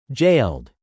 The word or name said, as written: jailed